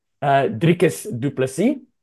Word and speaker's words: Dricus du Plessis: Dricus du Plessis. – Yeah. – And you've got the R. You have to have the R there as well.